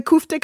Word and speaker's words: kofte: Oh, how do you pronounce it? The Kafkas? – Oh, the kofte kebabs? – Yes.